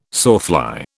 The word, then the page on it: sawfly: British English